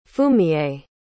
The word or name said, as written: fumiye